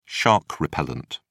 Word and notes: shark repellent: British English

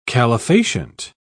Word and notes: calefacient: British English

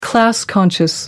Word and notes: class conscious: American English

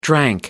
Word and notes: drank: American English

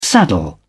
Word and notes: saddle: British English

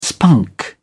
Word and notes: spunk: British English

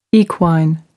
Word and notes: equine: American English

